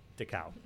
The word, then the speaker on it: Dachau: Dachau? I don't know if I'm saying it right. Dachau. – Dachau. – Yeah, yeah. Dachau. That's what it was.